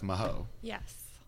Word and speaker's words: Mahoy: And before you keep talking or start talking, people consistently call you Steph Maho. – Yes. – Tell everyone the proper way to say your name. – It is Mahoy, like with a Y on the end, like Chips Ahoy.